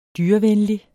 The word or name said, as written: dyrevenlig